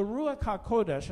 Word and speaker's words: Ruach HaKodesh: So the Ruach HaKodesh, that's how you say it in Hebrew, really means the holy wind, the wind that is holy or set apart.